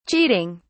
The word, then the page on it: cheating: British English